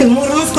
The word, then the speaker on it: Мурманск: Насколько я знаю, около Мурманска. Мурманск у нас стоит около Баренцева моря. – Не так. Мурманск. – Мурманск? – Ударение на другом слове. Все рыбаки так говорят, так же, как компас и компас.